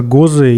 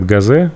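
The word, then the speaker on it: Gose: Конечно, Гозы и Берлинер Вайс. Дортмундский лагерь. – Но то, что ты ударение делаешь правильно, это удивительно. Почему все говорят Газе?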